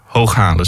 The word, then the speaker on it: Hooghalen: Want je zei al het oorspronkelijke circuit liep dus door Hooghalen. Zeg ik dat goed? – Dat klopt helemaal, ja.